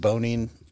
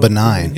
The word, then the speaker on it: Bonine: I've always heard it pronounced Bonine. – Bonine? – Yeah, Bonine. B-O-N. I-N-E, I think. – Yeah, I was saying benign.